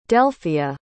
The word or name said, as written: delphia